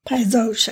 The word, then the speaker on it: Paysage: So this particular is a 2018 Chateau Paysage. I had to remember how to pronounce that. Did I say it right? – Paysage. – Paysage? Paysage. That's right. – Paysage, actually.